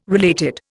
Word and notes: related: Indian

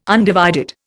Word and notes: undivided: Indian

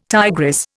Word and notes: tigress: Indian

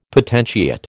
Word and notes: potentiate: American English

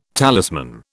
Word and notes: talisman: British English

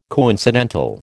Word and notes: coincidental: American English

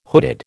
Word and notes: hooded: American English

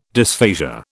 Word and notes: dysphasia: British English

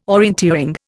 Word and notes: orienteering: Indian

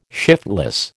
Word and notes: shiftless: American English

